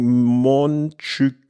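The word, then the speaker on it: Montjuïc: Er hat ein Sprachsample mitgeschickt. Äh, vereinfacht würde er sagen, Monchück, Schön ausgesprochen.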